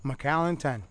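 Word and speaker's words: Macallan: How do you say it? – McAllen 10.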